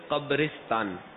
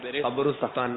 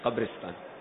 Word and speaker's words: قبرستان: اچھا, اس کا صحیح تلفظ ہے قبرستان, قبرستان نہیں قبرستان.